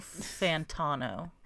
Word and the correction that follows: Fontana: No, it's Fontana, I think.